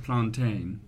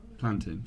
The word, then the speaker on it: plantain: In West Africa, it's pronounced plantain. But maybe in Afro-Caribbean culture, it's plantain.